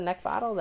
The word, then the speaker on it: Neckvatal: You can also find me on Twitter, LinkedIn, and YouTube by looking at my name, which is Brenda Neckvatal. That is spelled N-E-C-K, like the thing you want to choke, V-A-T-A-L.